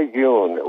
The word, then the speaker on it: Yun: Hannah Yun. It's spelled Y-U-N.